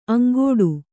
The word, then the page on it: ಅಂಗಾಡಿ: kannada